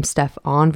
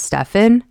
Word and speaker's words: Stephen: Not only did they mispronounce his name by calling him Stephon versus Stephen, that is just the start.